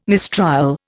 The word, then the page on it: mistrial: Indian